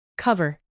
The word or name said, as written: cover